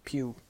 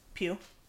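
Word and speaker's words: Pugh: How do you say her name? Pugue? – Pugue. – Pugue. – Like pew, pew, pew.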